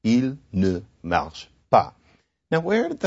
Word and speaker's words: pas: Classic example of this is the French negative marker pas, spelled P-A-S and pronounced pas.